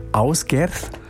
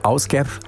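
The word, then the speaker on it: Ásgeir: von Ásgerð. Ja, spricht sich so. Ásgerð schreibt sich Asgeir.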